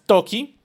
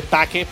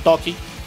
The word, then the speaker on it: Taake: Entonces, de Toki, que se escribe Taake, y todo el mundo le dice Taake pero los manes lo pronuncian Toki.